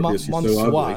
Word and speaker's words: Mansoor: How do they pronounce his name? – Mansoor's camera. – Mansoor, that's it.